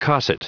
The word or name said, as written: cosset